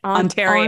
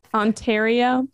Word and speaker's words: Ontario: How do you say it? – Ontario. Ontario. It's a hard A. – Ontario.